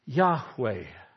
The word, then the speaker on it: Yahweh: But the best way that we think to pronounce it is Yahweh. You say, yeah, but I heard His name was Jehovah. Yeah, but there's no J or V in the Hebrew alphabet.